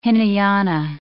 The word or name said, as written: Hinayana